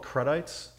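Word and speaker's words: crudités: That's how you pronounce that, crudité? – Yeah, well, I believe the fancy people call it crudites. – Crudites, yeah, that sounds good. That's probably how I'd pronounce it.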